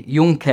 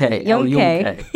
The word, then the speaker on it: Yunque: So the ending is more like K, Yunque. – Yunque.